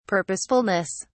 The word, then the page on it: purposefulness: British English